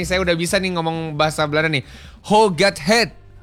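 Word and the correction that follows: Hoe gaat het: Huhat het.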